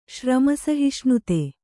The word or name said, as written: ಶ್ರಮಸಹಿಷ್ಣುತೆ